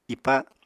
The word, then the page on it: ipa: apatani